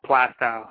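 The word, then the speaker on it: Plaistow: Yeah, they don't pronounce the I. It's Plastow. I don't know why.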